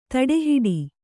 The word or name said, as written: ತಡೆಹಿಡಿ